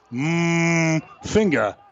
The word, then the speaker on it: Mafinga: They said you got to leave the M in there, like mmm finger.